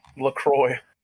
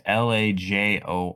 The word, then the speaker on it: LaJoie: LaCroix. – No, it's LaJoie.